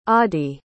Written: audie

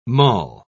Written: mull

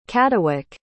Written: caddawyc

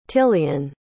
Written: tilian

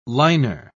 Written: liner

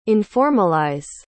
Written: informalize

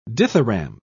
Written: dithyramb

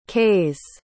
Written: kase

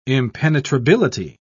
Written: impenetrability

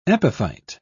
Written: epiphyte

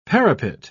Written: parapet

